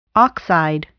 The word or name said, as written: oxide